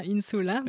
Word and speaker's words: Insula: Pourquoi, pourquoi ce nom Insula, donc qui s'écrit Insula mais qui se prononce comme en latin Insula.